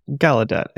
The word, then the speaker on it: Gallaudet: I'm sure is it Ga- Ga- Gallaudet or something? It's some French thing, right? – Gallaudet. It's fine. All right. So ga- the the short version of it is Gallaudet firmly backed Grusch's claims.